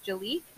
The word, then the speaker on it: Jaliek: And it is the disappearance of a 12-year-old boy, and his name was Jaliek.